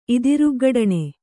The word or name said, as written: ಇದಿರುಗ್ಗಡಣೆ